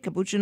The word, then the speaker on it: capuchin: Like all their friends probably want to come over to your house and hang out with Priya the capuchin. Is that how you say it? Capuchin monkey? – Yep.